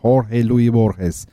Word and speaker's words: Jorge Luis Borges: Tam adı Jorge Luis Borges. Evet o şekilde okunuyormuş bu.